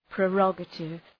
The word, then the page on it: prerogative: prı’rɒgətıv